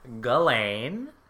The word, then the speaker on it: Ghislaine: How do you say her name? – It's Ghislaine. – Oh. – Not Ghislaine.